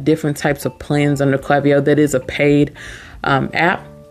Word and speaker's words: Klaviyo: The last but not least app, Klaviyo. Klaviyo, spelled with a K. K-L-A-V-I-Y-O. Let's talk about Klaviyo because it's one of my favorite apps.